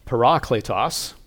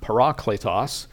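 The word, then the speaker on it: parakletos (παράκλητος): It's a transliteration of, let me get it right, parakletos. That's the Greek word, parakletos. And so other cultures, English, for example, has just transliterated it. We still kind of pronounce it like that, but we make it more like our language, so we just say paraclete.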